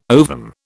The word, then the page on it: ovum: British English